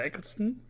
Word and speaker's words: Eccleston: Ähm, dann auch Christopher Ecclestone, wahrscheinlich, oder Eccleston, wie spricht man ihn aus? Eccleston.